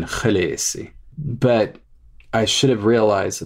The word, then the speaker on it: Khaleesi: In the books, Khaleesi is spelt K-H-A-L-E-E-S-I. – You have an E-E and then an I. They can't be pronounced the same because they're spelled differently if I want to honor George R. R. Martin's spellings. That is, if something is spelled differently, it's supposed to be pronounced differently. – So the double E in the middle should not sound the same as the I at the end.